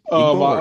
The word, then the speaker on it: Igbo: So it's spelled I-G-B-O. – Right. – And it's actually Igbo. That's how you say it, Igbo. Mm. Now, when the colonialists came to Nigeria, they couldn't say the word, the sort of a hard GB sound. And so they just said Igbo, I-B-O.